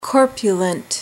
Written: corpulent

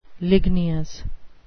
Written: ligneous

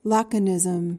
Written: laconism

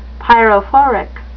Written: pyrophoric

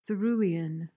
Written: cerulean

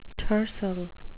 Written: tercel

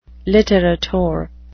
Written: litterateur

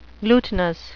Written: glutinous

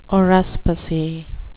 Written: haruspicy

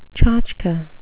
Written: tchotchke

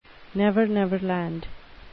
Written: never-never land

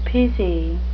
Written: specie

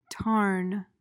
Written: tarn

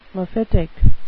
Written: mephitic

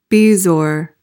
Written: bezoar

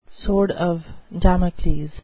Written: sword of damocles

